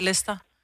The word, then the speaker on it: Leicester: Altså, der er jo bare nogle ting, du ikke udtaler. Det staves jo også sådan noget Leicester, men bliver bare udtalt Leicester.